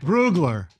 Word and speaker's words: Brugler: By the way, The Athletic, Dane Bugler, who Bugler, I don't know how to pronounce it. – Bugler. – What? Brugler. I said Bugler. – Brugler. Yes, you did.